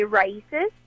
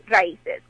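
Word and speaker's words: RAICES: To say it in English tone, you could say raíces, and then in Spanish it would be raíces, which means root in Spanish. And that raíces stands for the Refugee and Education Center for Refugee and Immigrant Center for Education and Legal Services.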